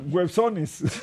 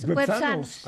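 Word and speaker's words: güepsanos: Websones. – No, lo dijo bien Andrés. Websanos.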